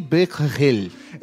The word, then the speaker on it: Becquerel: Então, primeiro, na Alemanha teve o cientista alemão que trouxe o raio-x, depois o, o Henry Blackwell, que não consigo pronunciar o nome dele. É, Henry Henri Becquerel.